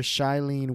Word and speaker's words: Shailene: Shanae Woodley. Or Shailene Woodley as Mary Jane Watson.